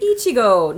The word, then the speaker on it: Ichigo: Ichigo. – Oh. – Ichigo. – It's Go with the... There's a line over the O. – It's because it's probably saying it's a long O sound.